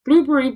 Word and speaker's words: Blubrry: It's spelled B-L-U-B-R-R-Y. Blueberry, but without the E's, like the fruit.